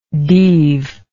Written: deave

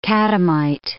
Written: catamite

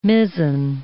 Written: mizzen

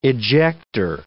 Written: ejector

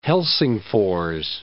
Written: Helsingfors

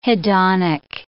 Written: hedonic

